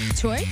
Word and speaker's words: Thuy: Her name is, how would you pronounce this? T-H-U-Y. – Toy? – Toy Trang.